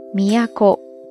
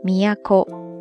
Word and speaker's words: 都: 那里边提到了有一个单词，在这儿要跟大家分享一下，就是京都的“ 都”，在日语当中读作。 miyako miyako miyako 这个单词大概有三个意思。